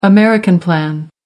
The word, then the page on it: american plan: American English